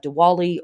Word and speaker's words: Diwali: we have Diwali or Deepavali. It's pronounced both ways.